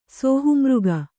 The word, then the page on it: ಶಹಾಮೃಗ: śahāmřga